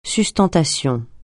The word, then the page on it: sustentation: francais